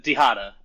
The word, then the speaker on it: Dihada: I don't know how to say it. – Dehada, I believe. Dehada. At least that's what Zane. That's what Zane calls it. And he's the one that got me and a bunch of other people on the deck. So I'll default to that pronunciation for now.